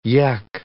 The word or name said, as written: yech